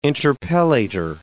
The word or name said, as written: interpellator